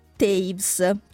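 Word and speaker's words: Toews: L'ha scritto Miriam Taves, te lo pronuncio così perché è scritto Toevs, però si legge Taves in modo un po' insolito, pubblicato dalla Marcos y Marcos.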